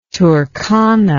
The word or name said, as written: Turkana